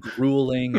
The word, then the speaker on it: grueling: I mean, there's the word grueling. It rhymes with cruel.